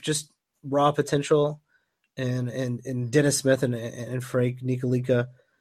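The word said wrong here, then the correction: Ntilikina: Nilakina. – I heard the N is silent. I read that somewhere. Frank Nilakina, so.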